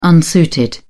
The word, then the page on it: unsuited: British English